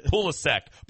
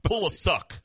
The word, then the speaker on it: Pulisic: Pulisic. Pulisic.